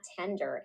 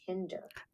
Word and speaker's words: Tinder: It's not Tinder, it's Tinder.